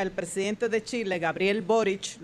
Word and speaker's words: Boric: lo pronuncio así porque Consuelo ha insistido, no. Consuelo dice que se pronuncia CH, no con C, Boric. Sí, porque me llamaron la atención a mí porque yo dije Boric.